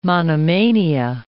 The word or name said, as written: monomania